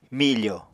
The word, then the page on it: mifò: Apatani